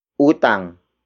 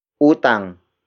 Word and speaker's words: utang: For example, nabayaran ang utang namin. Nabayaran ang utang namin. Nabayaran ang utang namin. It means our debt has been paid. Word for word, it's nabayaran ang utang namin. Nabayaran ang utang namin.